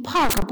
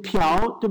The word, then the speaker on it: Park: 在英文里面我们都看到是 Park，P A R K Park，所以它读音肯定是接近 Park，不可能接近朴，对吧？